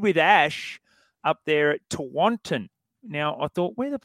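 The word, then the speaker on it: Tewantin: Tawantin, yes.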